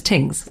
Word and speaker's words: Tings: Things without the H. If you spell it all out, that's what it is. Because my name is Tings.